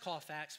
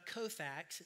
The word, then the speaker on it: Colfax: See, my dad has 10 acres of land in Colfax, North Carolina, and it is pronounced Colfax because I'm from there. It's not Kofax. It's not all these other Yankee ways that you can do it. It's Colfax. You don't say Colorado.